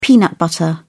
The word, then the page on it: peanut butter: British English